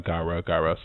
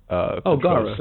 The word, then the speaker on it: Gaara: I only say that because Gaara from, I'm said that wrong. It's like Gaara, something like that. From Naruto. – Gaara.